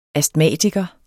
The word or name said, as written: ASTMATIKEREN